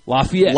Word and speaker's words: Lafayette: Lafayette. – Lafayette is how you pronounce that word.